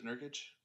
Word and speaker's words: Nurkic: How do you say his name? Yusevich, I believe is how it's pronounced. Can't remember. – Yusuf Nurkic? – Yes, Nurkic, sorry.